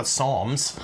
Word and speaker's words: Psalms: I was like reading the Psalms. I'm just kidding. P is silent, like in pterodactyl.